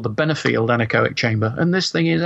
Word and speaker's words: anechoic: Tell us about the anechoic chamber. Have I pronounced that right? Anechoic.